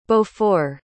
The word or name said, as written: beaufort